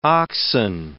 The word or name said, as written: oxen